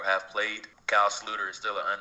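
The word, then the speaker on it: Sloter: JR, so you got Case Keenum, Teddy Bridgewater, Sam Bradford, and Kyle Sluter, as it's pronounced.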